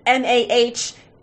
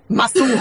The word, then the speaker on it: Mason: His name is Mason, but in the book it's pronounced MAH-SOON. So I am assuming it rhymes with bassoon. – Masoon. – Masoon. Yeah. – Masoon. Except Masson.